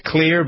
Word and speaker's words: Xlear: Pronounced Clear, but spelled X-L-E-A-R.